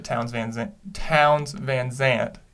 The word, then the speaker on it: Townes Van Zandt: Starting off, I do want to kind of go into Townes Van Zandt.